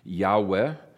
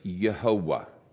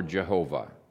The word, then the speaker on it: Jehovah: Uh, if the vowel points are inspired, as I think it is, um, then you would pronounce this Yehovah or Jehovah. Uh, either one is uh, a legitimate uh, pronunciation of that. And uh, there's three syllables. If you look even of how God forms the foundation for many of people's names, uh, it's one of the proofs that there's three syllables, not two.